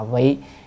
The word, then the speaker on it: vị: chữ đó phải đọc là vị ngã kim vị Bồ Tát đạo, là con nay vì Bồ Tát đạo chứ không phải là con nay hành Bồ Tát đạo, vi là hành, làm Bồ Tát đạo.